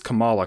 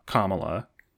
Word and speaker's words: Kamala: It is Kamala Khan. – Right. – Not Kamala. I understand it's spelled the same, pronounced differently.